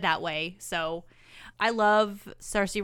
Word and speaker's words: Saoirse: And all the while, he is also romancing a girl named Agatha, played by Saoirse Ronan. – Is that how you say her name? Mm-hmm. I always thought it was Sorsa, but I don't think I've ever heard anyone say it until today.